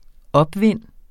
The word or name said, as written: opvind